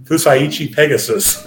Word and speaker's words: Fusaichi Pegasus: Fusaichi Pegasus.